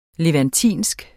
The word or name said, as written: levantisk